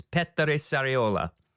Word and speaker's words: Petteri Sariola: It's PETTERI SARIOLA. And if you're a typical American and you can't roll your R's, he's usually cool with being called Pete.